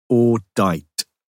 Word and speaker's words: audite: Audite, Latin, verb, pronounced audite, meaning I hear, I listen, I learn.